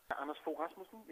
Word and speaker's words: Anders Fogh Rasmussen: And also that the character of the villain is based on Danish Prime Minister Anders Rasmussen. Is that how you pronounce it? – Anders Fogh Rasmussen, yes.